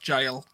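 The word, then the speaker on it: gaol: Adam Kindon as well said the jail is pronounced jail, J-A-I-L. – Aha, uh-huh, that's correct. – So there you go. – Yeah. I hear so many people call it the gal, though, to be fair, because it's spelled with a G, but... – Mm. – Yeah, because it's G-A-O-L, G-A-O-L, isn't it?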